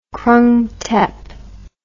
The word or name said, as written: Krung Thep